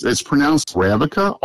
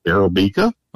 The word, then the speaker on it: Arabica: Well, it's pronounced Arabica, or some people pronounce it Arabica.